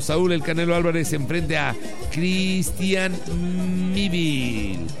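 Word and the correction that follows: Mbilli: Se pronuncia Mbilin. – Ah, sí, cierto. Mbilin. Gracias, Benito.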